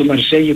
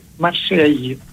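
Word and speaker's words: Marseille: Στα γαλλικά προφέρεται Μαρσέγι. Ναι. Ακούγεται το γη, τέλος πάντων. – Ναι, και δεν ακούει, δεν προφέρεται το τελευταίο γράμμα, αλλά...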